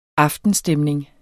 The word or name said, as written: aftenstemning